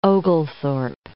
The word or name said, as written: Oglethorpe